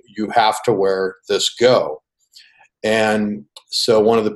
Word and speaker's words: gho: And so the men wear something called a go. I believe it's spelled G-H-O.